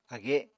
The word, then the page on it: age: Apatani